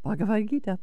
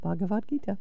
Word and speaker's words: Bhagavad Gita: But Bhagavad Gita, Bhagavad Gita, Bhagavad Gita.